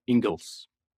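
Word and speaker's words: Inglis: Alexander Ingalls. Mm-hmm. It's spelled I-N-G-L-I-S, I believe, but pronounced Ingalls.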